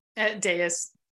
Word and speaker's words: Dais: Dais.